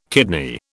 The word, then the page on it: kidney: British English